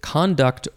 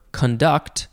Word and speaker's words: conduct: And the second form is, you could pronounce it conduct or conduct.